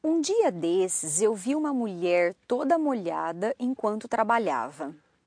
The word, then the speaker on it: mulher: Mulher. – Mulher. And that's spelled M-U-L-H-E-R. And so it's just from that Spanish mujer.